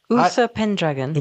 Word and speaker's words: Uther Pendragon: So he is out there, and one of the primary knights that he is observing is Uther Pendragon? – Yeah. – Is that how we pronounce it? Because Uther Pendragon.